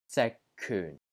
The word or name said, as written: ZIK6 KYUN4